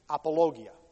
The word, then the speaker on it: apologia: You just pronounce it with a little bit of a different accent in Greek, apologia. But it's apology.